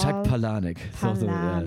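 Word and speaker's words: Palahniuk: Er wird anders geschrieben, als er ausgesprochen wird, aber ausgesprochen wird er Palanek.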